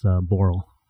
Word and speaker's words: Borrel: Well, my favorite bar / coffee shop / restaurant is Borrel. – How do you spell that? – B-O-R-R-E-L.